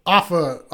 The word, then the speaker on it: Afa: Afa? – Afa. – Am I saying it right? Because I don't really murder the name.